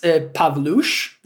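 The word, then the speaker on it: Pawlusz: That is, it's pronounced Pavlush. That's my last name, minus the EK, don't worry about it.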